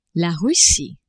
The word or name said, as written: Russie